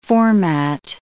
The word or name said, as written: format